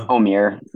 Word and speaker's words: Omier: I have not heard the report on Omier, is it pronounced Omier? – I think it's just Omier.